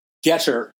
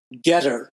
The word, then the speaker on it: Gettr: Another new social media platform we're now on is, I think it's pronounced Getter. G-E-T-T-R. Getter. G-E-T-T-R. You can join that too.